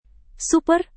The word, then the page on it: super: Indonesian